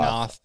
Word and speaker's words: Noth: So Mr. Big, played by Chris Noth. Noth? Noth? I don't know how you say his name. I've always said Noth. Noth.